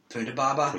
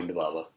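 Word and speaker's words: Ponda Baba: And Chunda Baba. – Yeah, yeah. – I said his name right. – I think it's Punda Baba. – Punda Baba.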